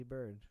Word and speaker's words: Byrd: Byrd. – Admiral yep. E. Byrd? – Admiral E. Byrd, and Byrd is spelled B-Y-R-D.